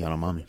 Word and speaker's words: Yanomami: How do you say the guys' names from Guyana? Yanomami? – Yeah, the Yanomami.